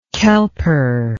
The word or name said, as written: Kelper